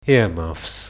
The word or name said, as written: earmuffs